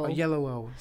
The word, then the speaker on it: Oyelowo: Oyelowo. – Oyelowo, sí.